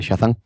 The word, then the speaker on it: Shazam: En verdad, en verdad es con CH, ¿vale? nosotros lo pronunciamos así, el Shazam.